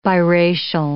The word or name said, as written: biracial